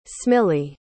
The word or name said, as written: smilie